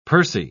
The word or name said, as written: pursy